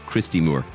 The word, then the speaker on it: Christy Moore: and learned rebel songs directly from Crystal Moore. Crystal Moore? Christy Moore.